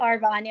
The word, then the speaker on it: Arbonne: So let's touch upon your Arbonne, right? That's how you say it? – Arbonne, yeah.